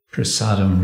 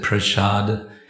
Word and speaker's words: prasada: we are prasadam religion, kitchen religion. The word prasada, and we often pronounce it prashad because we like to sound Bengali. Sanskrit would be prasada.